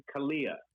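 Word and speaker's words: Calleia: And the look and everything, and I'm not trying to pronounce his last name, but Joseph Calleria? – I think it was Joseph Kalia. – Kalia. – Right? – It was said in the trailer, and Kalia. Yeah, I think you're right. Yeah, Joseph Kalia.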